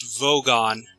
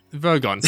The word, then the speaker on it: Vogon: It's pronounced Vogon. – Vogon, yeah.